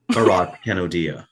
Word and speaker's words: Bharat Kanodia: Bharat Kanodia.